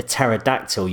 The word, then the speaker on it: pterodactyl: Why can't you hear a pterodactyl using the bathroom? – Don't know. – Because the P is silent.